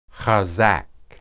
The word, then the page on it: hazaq: hebrew